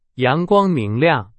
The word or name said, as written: 阳光